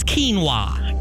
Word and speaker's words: quinoa: Quinoa. Oh. – Quinoa, Mom. It's quinoa.